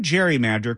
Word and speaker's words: gerrymander: But when the term gerrymander caught on, it wasn't with the hard G sound of Gerry's surname.